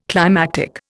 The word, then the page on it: climactic: Indian